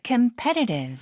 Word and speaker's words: competitive: I started to get competitive. – Very good. 한 번만 더요. – I started to get competitive. – Competitive. 이렇게 T 발음 설탄음으로 잘 발음해 주시고.